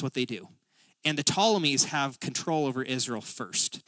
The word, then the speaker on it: Ptolemies: And the kingdom in the south is called the Ptolemies. That's how you pronounce that weird P word up there, the Ptolemaic dynasty.